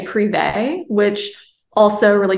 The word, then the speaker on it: Prive: And thank you for pronouncing it correctly. – Yes, not privy, Prive. – Yeah, not privy.